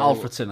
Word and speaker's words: Alfreton: Alfreton, I think it's pronounced.